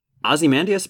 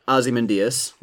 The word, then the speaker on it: Ozymandias: Now, that's a problem I have too, actually, because you're pronouncing it Ozymandias, which is how it's said in the beginning of the film. – Yep. – And by the end, and by the end, they're calling him Ozymandias.